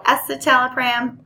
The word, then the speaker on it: escitalopram: And I've heard some pretty interesting pronunciations of escitalopram, but it's really the letter S and then citalopram.